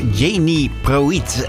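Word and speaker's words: Jeannie Pruett: En het mooie van het internet is natuurlijk ook dat ze erbij schrijven hoe je dat moet uitspreken. Want haar naam is eigenlijk Jenny Proiet.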